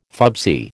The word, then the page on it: fubsy: American English